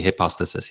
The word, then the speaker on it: hypostasis: But in the singular, it would be hypostasis, yeah.